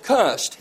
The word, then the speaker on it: cussed: Now, although Forrest cussed, and that's the good South Georgia pronunciation of cursed, but although Forrest cussed, he did not always get away with it.